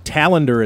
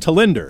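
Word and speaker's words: Tallinder: Henrik Talander at the time. It was really pronounced Talinder.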